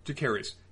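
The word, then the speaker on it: Jacaerys: Jacarys. – Seems like Jacarys, on the other hand, was really down with that goat getting burned.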